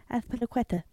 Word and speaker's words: Azpilicueta: Azpilicueta.